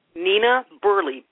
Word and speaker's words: Burleigh: Nina Burley? – Nina Burley, B-U-R-L-E-I-G-H.